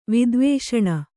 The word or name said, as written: ವಿದ್ವೇಷಣ